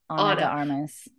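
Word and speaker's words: Ana de Armas: I'm so sorry, I can't pronounce her Anna de Armas.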